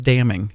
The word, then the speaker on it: damning: Okay, damn is pronounced damn, even though it has an N on the end. – Why? – I don't know. I didn't make the rules. So it's damning.